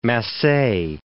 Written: masse

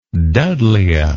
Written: Dudleya

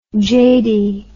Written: jady

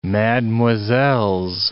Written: mademoiselles

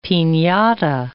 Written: pinata

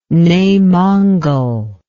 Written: Nei Mongol

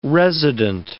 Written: resident

